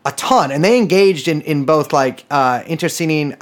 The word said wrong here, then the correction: internecine: I do not think I pronounced that correctly. The kind of warfare where you kill your friends too. Internecine.